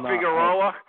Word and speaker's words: Figueroa: I heard it was going to be Figueroa. I don't know how to say his name properly. But Omar Figueroa at first. – Oh, Figueroa.